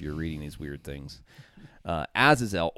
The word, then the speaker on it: Azazel: Azazel. I don't know if I'm saying that right. Do you know, is that the right way to pronounce that? – It sounds right to me. – Azazel.